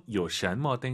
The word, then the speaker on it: 有什么: 요샨머 땡땡마 이제 많은 분들께서 아실 것 같아요. 이제 발음을 더 강조해드립니다. 또 삼성이성 요샨 요는 절대 올라가면 안 돼요.